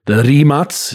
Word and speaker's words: Rimac: The roots, the heritage, the Rimac is how it's pronounced in Croatian.